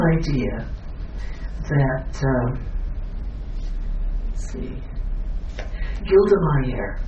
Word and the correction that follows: Golda Meir: Golda Meir, pronounced wrong, who was head of Israel during the 1973 war.